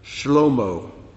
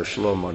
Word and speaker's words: Shlomo: Shlomo, which is how you pronounce the name Solomon in Hebrew.